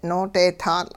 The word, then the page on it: noodeetaatL': Hupa